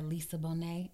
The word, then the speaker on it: Lisa Bonet: Like, my type of white dude is like the white dude that Lisa Bonet.